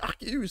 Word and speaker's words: Arceus: On a vraiment une grosse inspiration par rapport à Palworld sur du Pokémon Arceus ou Arceus vous allez sûrement pouvoir m'insulter sur les commentaires et sur les chats parce que je le prononce mal. Il y a même ma fille qui m'a engueulé tout à l'heure qui m'a dit non, c'est Arceus, c'est parti.